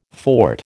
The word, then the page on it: fort: American English